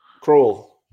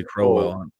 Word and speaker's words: Kroll: How do you say the last name? Well, Kroll. – Kroll. I always say Crowell. Kroll.